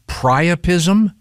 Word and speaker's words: priapism: and they would get is it pronounced priapism? – Mm-hmm. Yeah.